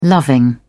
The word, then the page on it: loving: British English